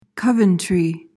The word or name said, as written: coventry